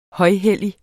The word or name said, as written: højhellig